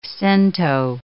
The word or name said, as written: cento